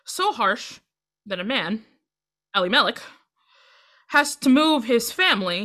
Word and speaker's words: Elimelech: The pronunciation Elimelech sounds like the Hebrew words for "my God is king."